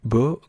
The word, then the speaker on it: Baux: Vamos a ver si lo puedo pronunciar, Bo Gris Gris and the Apocalypse. Bo se escribe B-A-U-X, así que supongo que en francés la E-A-U, creo que se pronuncia algo así como Bo, en fin.